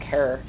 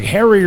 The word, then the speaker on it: Herer: Jack Herer. Rhymes with terror, for those of you pronouncing it wrong.